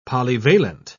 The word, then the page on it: polyvalent: British English